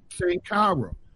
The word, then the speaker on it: Sankara: Sankara,